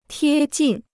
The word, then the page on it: 贴近: tiē jìn